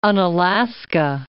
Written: Unalaska

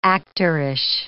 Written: actorish

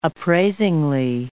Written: appraisingly

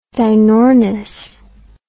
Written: dinornis

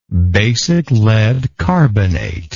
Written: basic lead carbonate

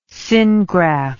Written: syngraft

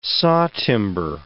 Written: sawtimber

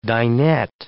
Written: dinette

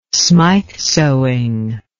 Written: smyth sewing

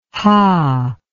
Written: hah

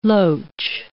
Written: loach

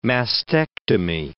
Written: mastectomy

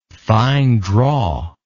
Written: fine drawer

